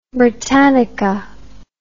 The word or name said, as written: Britannica